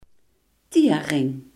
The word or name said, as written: tbc